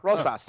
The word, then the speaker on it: Rosas: Sure, Rosas.